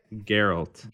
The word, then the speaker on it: Geralt: Geralt. – Geralt of what? – Rivia. – Okay, Geralt of Rivia.